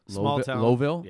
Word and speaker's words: Lowellville: L-O-W. It's spelled L-O-W? – V-I-L-L-E. – Lowellville? – Yeah, Lowellville.